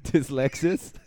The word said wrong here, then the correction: dyslexia: Dyslexis, like the car. It's dyslexia.